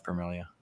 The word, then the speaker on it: Permelia: Welcome to Vision Pros Live, Permelia.